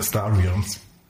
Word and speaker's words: Star Realms: Star Realms. So wird es ausgesprochen.